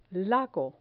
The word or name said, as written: lago